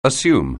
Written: assume

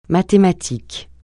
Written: mathématique

